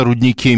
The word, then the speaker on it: рудник: Рудник, конечно, так правильно говорить, но надо понимать, что существует кроме русского языка профессиональный жаргон, и в профессиональном жаргоне действительно принято говорить, делая ударение на первом слоге.